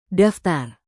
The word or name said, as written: daftar